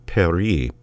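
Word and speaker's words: Péri: It's spelled P-E-R-I, not like the French pronunciation of Paris, but Perie, P-E-R-I, and was his last completed work, which he wrote at the age of 47.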